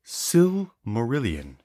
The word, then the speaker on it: Silmarillion: It is pronounced Silmarillion.